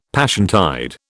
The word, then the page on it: Passiontide: British English